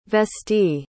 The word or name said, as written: vestee